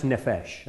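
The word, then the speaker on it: nephesh (נפש): So it's pronounced nephesh, okay? That's the Hebrew word for soul.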